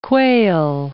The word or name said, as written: quail